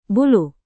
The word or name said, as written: bulu